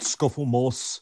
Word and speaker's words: Scufflemoss: What you've done, so mostly I'm on Instagram, just at Scufflemoss.